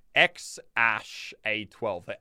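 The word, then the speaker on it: X Æ A-12: So the baby's name is XAEA12, but it's pronounced apparently XASH A12. The AE part in the middle is pronounced Ash.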